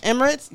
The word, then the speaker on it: Emirates: Emirates. Yeah, that's how you pronounce it.